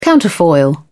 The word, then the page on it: counterfoil: British English